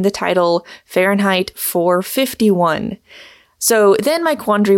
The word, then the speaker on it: Fahrenheit: So just going on record, it should be pronounced Fahrenheit 451.